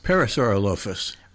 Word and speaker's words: Parasaurolophus: I'm sort of wrestling with how you pronounce this. Parasaurolophus. – Parasaurolophus. – Right.